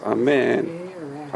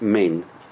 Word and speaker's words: amen: If you say if it's a segol, then it's amen. Amen. But it's not main. Main. Right? There's no a. There's no a sound.